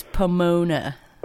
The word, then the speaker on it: Pomona: Basically, it was just the live show was filmed in Pomona, I believe that's how it's said. – Yes. – Or is it Pomona? – It's Pomona.